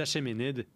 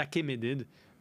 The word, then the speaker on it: Achéménides: Lui, c'est le chef des Achéménides ou des Achéménides. Tu peux le prononcer comme tu veux, c'est pas important.